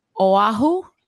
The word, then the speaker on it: Oahu: We are on Oahu.